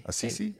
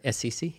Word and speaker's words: Assisi: Assisi? – Assisi, yeah.